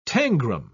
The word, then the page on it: tangram: British English